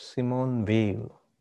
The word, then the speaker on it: Simone Weil: It's pronounced Simone Weil.